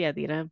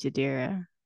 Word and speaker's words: Yadira: You know that, like, I always say Yadira, but that's not how, like, I talk to people. – How do you talk to people? – Like, I say my name's Yadira. – Oh, yeah. – Unless they're, like, obviously Hispanic, you know, like, speaking to me in Spanish, then I'll be like, oh, Yadira.